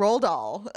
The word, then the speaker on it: Roald Dahl: Uh, all right, so the book was originally published in 1964, and it's written by Roald Dahl, I think is how you say it. Roald Dahl. – Roald Dahl.